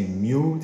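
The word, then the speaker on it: Mu: حرف میم یونانی رو بهش مو میگن و در انگلیسی میو تلفظ میشه که ام یو نوشته میشه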